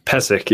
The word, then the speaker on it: Pesek: Pesek. Yeah. – Pesek. – P-E-S-E-K.